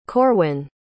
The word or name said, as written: corwyn